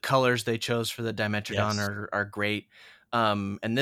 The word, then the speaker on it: Dimetrodon: Because another thing I'll be frivolously spending my money on, my hard earned money, will be the Grant and Dimetrodon. Am I saying that right? – Dimetrodon.